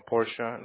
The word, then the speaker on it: Portia: Did I say it wrong, Portia? Like the car, right? – No, that's correct.